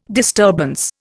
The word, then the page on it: disturbance: Indian